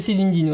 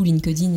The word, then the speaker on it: LinkedIn: Bon, après, c'est pas grave, en France, on dit beaucoup LinkedIn, et, euh, c'est accepté, hein, on, reconnaît, mais bon, voilà, la vraie prononciation, euh, c'est en fait, c'est un passé simple, en fait, c'est pour ça qu'on zappe le K et le E.